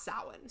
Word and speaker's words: Samhain: And this one is spelled like Samhain, right? – Samhain. Yes. – Is what it looks like. – Yes. – But so, it's pronounced Samhain.